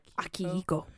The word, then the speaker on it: Akihiko: Akiko. Is that right? – Akihiko.